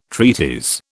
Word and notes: treatise: British English

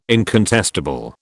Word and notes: incontestable: British English

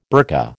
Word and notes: burka: American English

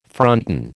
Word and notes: fronton: American English

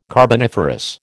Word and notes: carboniferous: American English

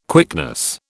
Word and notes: quickness: British English

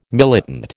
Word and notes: militant: American English

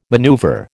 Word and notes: maneuver: American English

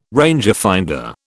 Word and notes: rangefinder: British English

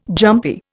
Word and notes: jumpy: Indian

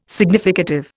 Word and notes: significative: Indian

